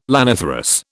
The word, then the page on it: laniferous: British English